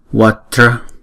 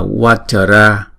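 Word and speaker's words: vajra: Chữ Vatra này là hai âm và mỗi âm là một nhịp ha. Vatra, Vatra. Vati, Vati. Vatra, Vati. Vatra. Chữ J này là J, J. Không phải, chúng ta không có đọc là Watara. Chúng ta đọc Watara là thành ba âm rồi, không?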